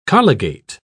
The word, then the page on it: colligate: British English